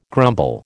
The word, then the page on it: grumble: American English